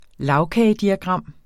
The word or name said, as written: lagkagediagram